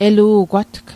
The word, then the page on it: elu'gwatg: Mi'gmaq